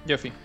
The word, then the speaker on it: Jaffe: Al menos eso lo dijo David Jaffe, Jaffe, no sé cómo se pronuncia. – El, Jaffe, Jaffe. – Jaffe.